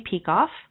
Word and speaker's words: Peikoff: And if you want to read more about it, then you can Google my name, Amy Peikoff. Last name is spelled P as in Peter, E-I-K-O-F-F.